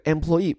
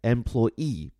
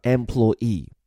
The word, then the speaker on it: employee: 所以后半句完整表述叫 employees were detained 或者是 are detained，这个 employee 表示雇员， it means someone who is paid to work for someone else，意思不难解，说一下发音，这个词呢其实有两种发法，一种像我这里念的 employee，重音放在最后一个音节 e，然后这个词的首字母 e 呢发的是 a 这个音，所以叫 employee。但你如果另有偏好，再送你一种读音叫 employ，注意哦，重音就放到第二个音节 ploy，然后前面这个 e 发的就是 e，所以别小看这个词哈，两种发音适合你，一个叫做 employ，另一个呢叫 employee。